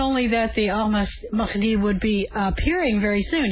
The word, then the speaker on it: Ahmadinejad: Ahmadinejad. – Well, the way to remember, is I'm in a jihad.